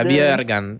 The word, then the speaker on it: Bjerkan: ¿Cómo se pronuncia esto? – Es como una borracha. Bjerkan.